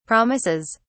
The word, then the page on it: promises: British English